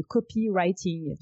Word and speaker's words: copywriting: On va parler de copywriting. J'espère que je le dis bien. – Oui, tu le prononces, tu prononces très bien.